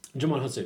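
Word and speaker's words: Djimon Hounsou: Jumon Hutsu.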